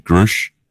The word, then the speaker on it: Grusch: it was David Grusch, I believe is how you pronounce his last name. It's G-R-U-S-C-H.